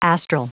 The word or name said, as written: astral